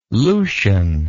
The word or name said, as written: Lucian